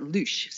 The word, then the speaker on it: louche: Lush. L-O-U-C-H-E. Lush. Yes. – I thought you pronounced it loosh, so I am surprised. – You could, but apparently you pronounce it like you would say whoosh.